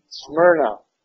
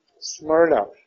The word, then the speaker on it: Smyrna: Smyrna. Smyrna. Look at the Y as if it were an I. RNA. Yeah. Smyrna.